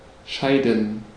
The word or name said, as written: scheiden